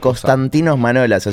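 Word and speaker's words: Konstantinos Manolas: Constantinos Manolas a su presentación en el Yarja de Emiratos Árabes.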